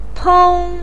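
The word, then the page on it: peng1: tone 1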